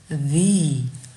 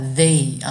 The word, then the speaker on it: the: 嘴唇呈微笑状往两边咧，一类似于 meet m e e t 里面的一，所以读的是 the，嗯，大家不要把它读成 t h 以外的 they 啊，注意一下是不一样的。我们读的是 the，OK，一个是 the，一个是 the，那怎么用呢？